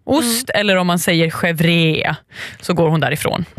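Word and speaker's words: chèvre: Och jag ska nog inte försöka mig på franska ursättningen. Chevre, säger man.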